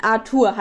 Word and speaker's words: Arthur: Oh, wie sag, was sage ich Arte? As, ich kann es nicht aussprechen. Arthur halt auf Deutsch.